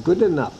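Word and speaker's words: Goodinough: So anyway, good enough.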